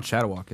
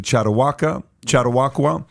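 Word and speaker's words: Chautauqua: Can you pronounce that for me? – It's not Chattawak, is it? – Chattawakwa Institution in New York.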